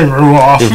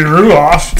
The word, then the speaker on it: Ruoff: I say Ruoff. – There's it's got to be Ruoff.